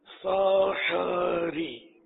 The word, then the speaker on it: سحری: دراصل وہ عربی تلفظ کے حساب سے تو احمد اور سحری یہ ہے اس کا تلفظ. ہا ساکن نہیں ہے, میرا خیال ہے مفتوح ہے, سحر سے ہوا نا سحر, وہ سحر تو جادو کو بولتے ہیں.